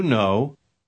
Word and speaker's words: know: No pronuncie la letra K en la última palabra. – Do you know? – Do you know?